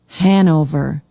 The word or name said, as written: hanover